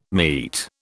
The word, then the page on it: meat: British English